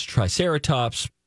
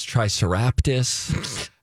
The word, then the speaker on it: Triceratops: And then we were talking about the time that Donna tried to pronounce Triceratops, and she thought it was Triceraptus.